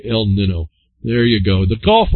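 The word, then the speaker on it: El Niño: Oh, this one's got an N-Y. It's actually pronounced El Nino,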